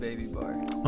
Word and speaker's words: bbybar: Yeah, it's just bbybar.